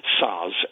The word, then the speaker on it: Saaz: They use a variety of hop that's grown specifically in that part of the world called Saaz, S-A-A-Z it's spelled, and any true Czech Bohemian Pilsner has that kind of hop, that hop variety in it, and it's pretty distinctive.